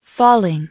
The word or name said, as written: falling